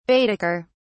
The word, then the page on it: baedeker: British English